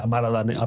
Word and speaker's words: Amara La Negra: Amara La Negra. Amara La Negra.